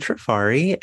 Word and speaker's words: Trafari: Yeah, my name is Rocky Trafari, and I am a travel blogger slash digital marketer who runs a travel blog known as The Rocky Safari, like rhyming with my name.